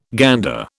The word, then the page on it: gander: British English